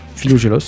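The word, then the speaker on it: Philogelos: Je ne sais pas si je le dis bien, Philogélos. – Si tu le dis bien.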